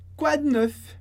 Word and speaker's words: quoi de neuf: En français, quoi de neuf? Quoi de neuf? Interessante que aqui a gente não pronuncie esse E. Então fica um D bem seco.